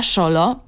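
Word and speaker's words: Xolo: Rasa psa Szolo pisze się ksolo, ale w Meksyku często X wymawia się jak szy.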